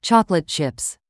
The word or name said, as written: chocolate chips